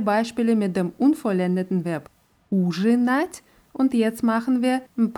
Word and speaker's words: ужинать: Bei dem Verb ужинать bleibt die Betonung auf dem ersten U in allen Formen.